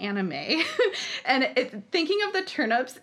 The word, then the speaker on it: anime: So shout out to any of our listeners who watch anime. – I don't want to be a poopy faced tomato nose, but I did take 3 years of Japanese in high school. It's pronounced anime.